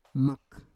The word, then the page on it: mak: Roon